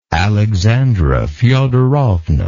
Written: Alexandra Feodorovna

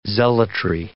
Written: zealotry

